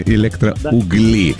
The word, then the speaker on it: Электроугли: Вот, электроугли или электроугли. Да. Пытались мы разобраться, если что, ударение на «у» электроугли, а жители города электроугли как зовутся, стало быть? – По мне так электроуглята, я не знаю.